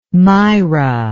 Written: mira

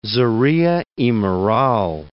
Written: Zorrilla (y Moral)

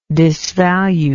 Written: disvaluable

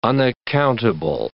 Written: unaccountable

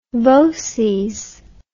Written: voces

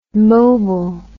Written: mobil

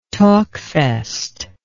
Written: talkfest

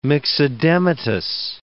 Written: myxedematous

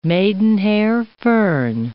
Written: maidenhair fern

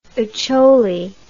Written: Acholi

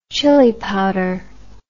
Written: chili powder

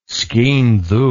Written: skene dhu

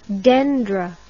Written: dendri

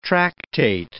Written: tractate

